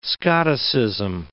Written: Scotticism

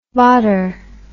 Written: varder

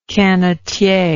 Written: canotier

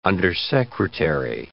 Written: undersecretary

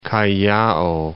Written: Callao

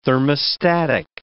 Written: thermostatic

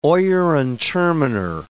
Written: oyer and terminer

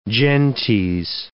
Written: gentes

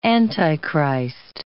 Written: Antichrist

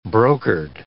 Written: brokered

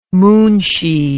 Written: munshi

